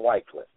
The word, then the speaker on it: Wycliffe: You have a fellow by the name of Wycliffe. Some people call him Wycliffe. It's spelled W-Y-C-L-I-F-F-E.